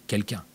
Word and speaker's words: KELK1: Orthographié, je vous le rappelle, K-E-L-K-1, prononcé quelqu'un.